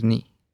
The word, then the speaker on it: nie: If you don't like beer and say, I don't like beer, you can say, "Ek vind bier nie lekker nie." Let's break it down by syllable. ek, vind bier nie, lekker nie. Ek vind bier nie lekker nie. The word nie means not. Ni. Ni.